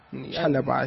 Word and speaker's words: Chalobah: Chalobah. – Oh, for real? – Yes. Chalobah.